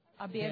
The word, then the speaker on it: обед: Обед. – Dinner? – Обед. – Обед. – Обед. – Обед. – Обед. – Обед. – Like abed. A bed, almost, or a bed.